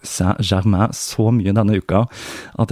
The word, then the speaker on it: Saint-Germain: Så for å få hjelp med dette, så snakket jeg med en kollega som er fransklærer og uttalen skal være noe i retning av Saint-Germain.